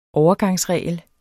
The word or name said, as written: overgangsregel